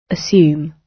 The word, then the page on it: assume: British English